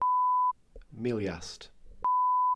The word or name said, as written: miliast